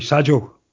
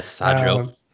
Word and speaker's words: Sadio: So, yeah, you know, obviously Sadio is his favorite player, or should I say Sadio. We have to pronounce it properly. – Right, yeah, Sadio.